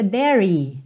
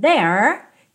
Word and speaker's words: there: Nunca diga dairy, dairy, dairy. Não, o último é não é pronunciado. Então, there termina ali, ó, com a língua enrolada, igual na porta com a perna esquerda.